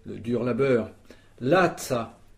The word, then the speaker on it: laca: La, L-A-S en français. Mm. Donc lazza, ça s'écrit L-A-C-A, puisque n'oublions pas que le C se prononce ts.